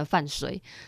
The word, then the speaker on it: 范睢: 那如果换成目这个字的话，应该说目这个部首的话，那这整个字呢就念成虽哈，虽然的那个虽。那就变成范睢，但我觉得范睢很难听，然后感觉好像真的很衰。那为什么会念成范睢呢？那是因为在《史记》的本传呢、喔，就里面是这样写，就写成“ 睢” 这个字。然后再按那个《资治通鉴》呐，哦，他也是这样写范范睢。